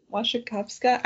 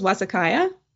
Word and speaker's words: Wasikowska: Wasikowska. I actually watched a video of her pronouncing her name because for some reason I thought it was Wasikaya, but it's not.